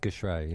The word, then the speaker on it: geschrei: And I did that little geschrei. I can't even pronounce it right. But the little... – It's geschrei, yeah.